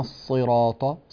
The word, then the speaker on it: الصراط: بالنسبة للصاد، اهدنا الصراط، صي، أعطي الصاد حقها من التفخيم، لا تجعلها سينا.